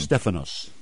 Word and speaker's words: Stephanos: You're using the Greek pronunciation. – Stephanos. – Yeah, Stephanos.